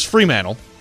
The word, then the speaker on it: Fremantle: Going to the mighty matchup of Hawthorn versus Fremantle. I believe that's how you say it. – I believe that's correct, yes.